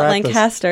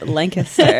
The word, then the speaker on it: Lancaster: It's not Lancaster. It's Lancaster. – While at FNM, was active in working with refugees and immigrants through awareness efforts, volunteer coordination, and fundraising in the Lancaster community and through the Lancaster County Refugee Coalition.